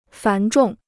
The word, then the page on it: 繁重: fán zhòng